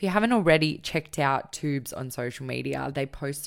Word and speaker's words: Twoobs: Yeah, so we're at Twoobs on Instagram. It's T W O O B S. There's a silent W in there.